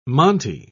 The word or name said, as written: monte